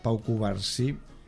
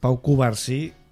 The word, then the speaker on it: Pau Cubarsí: Vuelve a ser que ayer vimos a Pau Cubarsí. ¿Lo he dicho bien? – Sí, sí, perfecto. Sí. Sí. – Es que ayer que en mi vídeo lo dije mal. Pau Cubarsí.